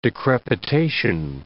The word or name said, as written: decrepitation